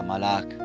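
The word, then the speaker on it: malak (מלאך): Miramos entonces que esta palabra enviado, dice en el verso 6, enviado, y en el hebreo que significa o, su, o se pronuncia malak, de una raíz que no se usa, y su significado es despachar como delegado, o específicamente de Dios, embajador.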